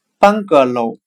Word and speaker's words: bungalow: 今天我们来学习单词 bungalow，b u n g a l o w，表示平房的含义。我们可以用谐音法来记忆这个单词 bungalow，它的发音很像汉语的半个楼，一半的半，一个的个，楼房的楼。